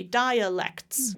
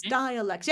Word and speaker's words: dialects: Yep, give that one space to breathe. That center syllable, give it its own space. Dialects, dialects. Okay. Does that make sense? Dialects. There you go. A little stronger on the first syllable.